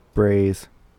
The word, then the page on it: brays: American English